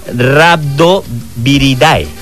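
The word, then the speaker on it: Rhabdoviridae: O sea, era de la familia Rabodiadari. Venga, ¿cómo se pronuncia? – ¿Rabo qué? – Rabdoviridae.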